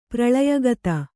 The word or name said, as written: ಪ್ರಳಯಗತ